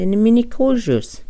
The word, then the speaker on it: Minikoju: South Dakota area are the Minikojus. Minikoju. Okay, we can pronounce that together. The Minikoju. Minikoju. Uh-huh. Watch that, P-W-M-P.